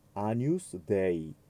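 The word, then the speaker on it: Agnus Dei: そして、アニュスデイ。この言葉、アニュスデイというのは神の子羊という意味ですね。この最後のところで主に呼びかけます。 GN はニャニューニョの発音。アニュスデイ、クイトリス。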